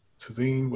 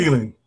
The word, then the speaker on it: Thielen: Wide receiver, Adam Tazine, whatever. – Thielen? – Adam Thielen? – Thielen.